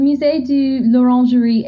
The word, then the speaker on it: Musée de l'Orangerie: Yeah, so the Musée de l'Orangerie, it's really hard to pronounce, I guess, as Americans here, but um, I absolutely love it.